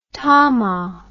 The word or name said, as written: tama